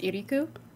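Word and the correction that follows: Ikiryō: Iriku. – Ikirio. – Oh, Ikirio. – Ikirio. It almost sounds like Ikirio.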